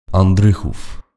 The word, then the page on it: Andrychów: Lehçe; [anˈdrɨxuf]